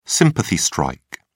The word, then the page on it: sympathy strike: British English